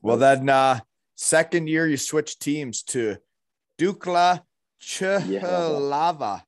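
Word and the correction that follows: Jihlava: Yihlava, yeah. Dukla Yihlava. Yeah, Dukla Yihlava. Yeah. So the J is silent, like in yogging. – Yeah, they... they say their J's are like a year there, so yeah.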